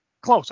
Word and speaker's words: Clowes: On page 80, if you're a friend of Daniel Close, no, I said his name wrong. Clowes? – Close. – Okay, so I did say it right.